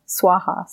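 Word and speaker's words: svaha: So the words are Om Tare, Tu Tare, Tu Re, Soha. Or Swaha, sorry. There's different pronunciations, Swaha.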